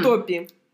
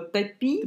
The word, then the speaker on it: Топи: Топи. – Я увидев это в названии, решила, что на ударение ставится на вторую буковку.